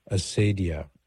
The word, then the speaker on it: acedia: It's called acedia, A-C-E-D-I-A. Or some people pronounce it the more the Latin way, acedia.